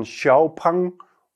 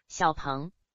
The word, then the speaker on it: Xpeng: Wir starten mit einem Xpeng oder wie die Chinesen aussprechen würden, Xiaopeng oder wie nochmal? – Xiaopeng.